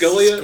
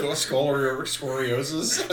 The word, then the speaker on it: scoliosis: Is it scolia? – Scoliosis.